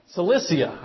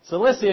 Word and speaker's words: Cilicia: Then you have, I feel like I'm saying that wrong. Am I saying that right? Cilicia. I knew I was saying it wrong. Cilicia, and you got Tarsus in that.